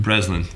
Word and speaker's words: Breslin: Breslin, okay.